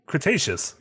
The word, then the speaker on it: Cretaceous: Cretaceous period. – get now you got me. Now you got me messed up. – Cretaceous. – Yeah, I said it right.